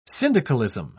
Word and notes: syndicalism: British English

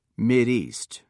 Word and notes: mideast: American English

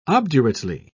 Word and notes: obdurately: British English

